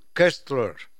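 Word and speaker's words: Koestler: El autor es Arthur Kessler. Se escribe Coestler.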